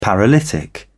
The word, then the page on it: paralytic: British English